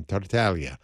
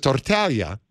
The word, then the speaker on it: Tartaglia: You know, your domain name, I just got to tell you, your web address, that's a tough one for people because Tartaglia doesn't sound anything like it's spelled in Italian with the G-L-I, you know? – But right. The word taglia means cut. So Tartaglia sounds like you're saying cut.